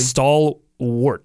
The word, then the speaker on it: stalwart: Is it stalwart? Am I getting the pronunciation correctly? – Stalwart. – That's what I was saying. I felt like I was saying that. Maybe I was putting a little bit of emphasis on the A the first time, but I can tell you who's not one of those things.